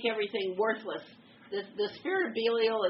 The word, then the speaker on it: Belial: The spirit behind that is called Belial. Some people call it Belial. In the Hebrew, it's Belial. So take your pick, which syllable you want to emphasize.